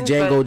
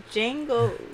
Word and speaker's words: Django: Django, what's the title? – Django Jane. – Yeah. – D-G-A-N-G-O. – The D is silent.